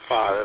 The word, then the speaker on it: pho: WB80, I see on my tablet that Walmart carries Instant Chicken Full, P-H-O, I know you pronounce it different than I do. How would I pronounce that? Pho. Pho.